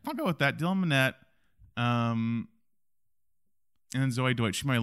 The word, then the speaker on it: Minnette: Dylan Minnette, yeah.